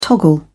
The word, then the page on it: toggle: British English